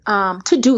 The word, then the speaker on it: Teuxdeux: It's called To-Do, but it's spelled T-E-U-X-D-E-U-X. So it's like fancy To-Do. I know, I was about to say, like, you're fancy. – Yeah, so it's just a spelling that's fancy.